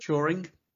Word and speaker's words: Turing: Turing, is it? – Turing, yeah.